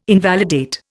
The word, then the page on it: invalidate: Indian